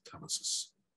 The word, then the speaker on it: telesis: So telesis or telesis, do you have a preferred pronunciation? – I usually pronounce it telesis.